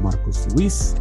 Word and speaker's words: Marcos Luis: One Mic Night is spelled O-N-E-M-I-C-N-I-T-E. You can follow me at Marcos Luis, M-A-R-C-O-S-L-U-I-S.